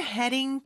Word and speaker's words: heading: I'm heading to bed 이었습니다. 자, 일단 I'm 발음으로 해준 다음에 I'm heading. D 발음은 여기 모음 소리 사이에 껴 있기 때문에 리을처럼 발음이 돼요. 그래서 heading. heading이라고 하는데 그 뒤에 이제 to가 오잖아요.